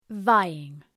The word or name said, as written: vying